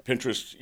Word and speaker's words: Pinterest: Are you saying Live, L-I-V-E, Action? – L-I-V-E, okay, Action. A place. They had a place on Pinterest. Is that the way you pronounce it? – Pinterest, right. – Pinterest. Did I say it wrong? – No, Pinterest. Pinterest. You're right.